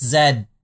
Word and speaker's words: Z: It's pronounced Zed because she's from Canada, but it's the letter Z.